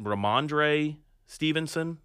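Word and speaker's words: Rhamondre Stevenson: Is it Ramondre Stevenson? – Yeah, he did a good job.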